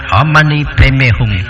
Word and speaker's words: Om Mani Padme Hum: Tạng ngữ đọc là Om Mani Padme Hum.